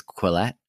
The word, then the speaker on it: Quillette: I think it's Quillette.